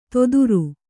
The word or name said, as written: ತೊದುರು